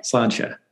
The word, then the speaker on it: Sláinte: Sláinte. – Sláinte. – Sláinte is right. Nailed it.